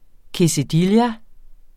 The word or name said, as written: quesadilla